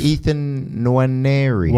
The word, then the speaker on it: Nwaneri: Ethan Nwaneri.